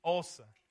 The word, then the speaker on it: Xhosa: It's home to the Xhosa people. That's how you say it. You can say it with me. Xhosa.